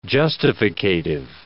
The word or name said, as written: justificative